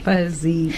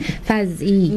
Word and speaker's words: Faz-E: Have I got the name right? – Faz-E. – Faz-E. Mm. Faz-E. – Faz-E. – Faz-E. – Yeah. – Like Faz-E? – Yes. – Oh, okay. – Like a fuzzy teddy bear.